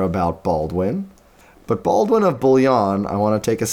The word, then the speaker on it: Bouillon: It's spelled B O U I L L O N.